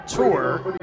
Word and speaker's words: Tur: New pitcher on the mound for the Aviators is right-hander Junior Tour. First name is spelled Y-U-N-I-O-R. Last name is T-U-R. He wears number 99, 26-year-old from Cuba.